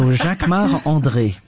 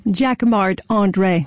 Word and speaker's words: Jacquemart-André: So Jacquemart-André. – And say it so we can understand it. – Jacquemart-André.